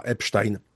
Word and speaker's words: Epstein: 30 stycznia 2026 roku amerykański Departament Sprawiedliwości opublikował najnowszą i ponoć już ostatnią część dokumentów z akt sprawy dotyczących Jeffreya Epsteina. Odczytam to nazwisko tak, jak to robią Amerykanie, wymowa angielska, choć ta nazwa Epstein, ta wymowa Epstein również występuje.